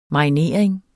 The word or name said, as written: marinering